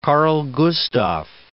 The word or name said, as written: Carl XVI Gustaf